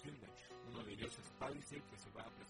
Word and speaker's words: Palisade: Se escribe P-A-L-I-S-A-D-E, pero se pronuncia Palisade porque pues es un nombre,